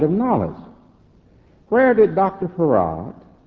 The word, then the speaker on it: Fard: who's called Farad. It's spelled F-A-R-D, but it's pronounced F-A-R-A-D, Farad.